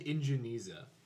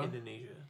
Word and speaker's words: Indonesia: How did you pronounce Indonesia? – That's at Indonesia. – It sounds like you just said Ingeniza. – Ingeniza. – In Geneva. – Indonesia.